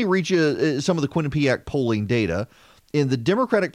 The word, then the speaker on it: Quinnipiac: K-W-I-N, Quinn, U-H, P-E-A-P. A-C-K, ACK, Quinnipiac, the Quinnipiac University National Poll.